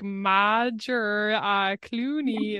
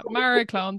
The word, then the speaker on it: Magheracloone: Because it's spelled in such an Irish way. Okay. If any abroad listeners, when they see that they're like Madge or Clooney, it's like, yeah, Maraclone. That's welcome to Ireland.